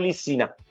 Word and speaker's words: Licina: Per Licinia, Licina, Lissi, guarda, ti dico Licina, come si scrive, ma è Lissina.